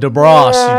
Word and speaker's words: DeBrosse: It's Derek DeBrosse. – Yeah, you screwed it up. It's DeBrosse. We Americanized it. – DeBrosse. You dropped the long E. – We dropped the Frenchism on it.